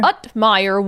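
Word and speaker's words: Uthmeier: Utmeyer warning would-be fraudsters.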